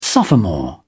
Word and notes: sophomore: British English

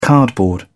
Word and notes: cardboard: British English

